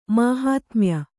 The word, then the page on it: ಮಾಹಾತ್ಮ್ಯ: māhātmya